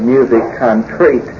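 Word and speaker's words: musique concrète: One area of music today is M-U-S-I-Q-U-E, capital C-O-N-C-R-E-T-E, and it's a French term. We'll pronounce it as you would in English, music concrete.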